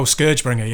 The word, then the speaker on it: ScourgeBringer: But I've also been playing another game whose name I can't sure how to pronounce. Scourgebringer. – Oh, Scourgebringer. Yeah.